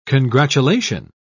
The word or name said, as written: congratulation